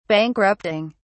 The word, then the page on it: bankrupting: British English